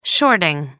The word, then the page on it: shorting: American English